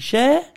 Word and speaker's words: Cher: How do you say it? – I can't remember, share. Mate. You've made me feel fucking really... Share? – Share. Sure, share. She went to go and see...